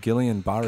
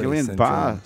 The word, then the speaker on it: Guillain-Barré: It's called Guillain-Barré Guillain-Barré syndrome.